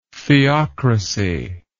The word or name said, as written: theocrasy